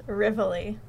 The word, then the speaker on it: Rivoli: It's actually pronounced Rivoli. – Rivoli. – Rivoli. – Okay, what was I saying? – Rivoli. – The Rivoli. Well, I'm saying it the Italian way.